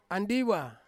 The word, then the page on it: andiwa: Ansus